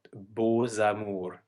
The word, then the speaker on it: beaux amours: And another one that doesn't sound like the original letter is beaux amours, where we have a silent X that turns into a Z.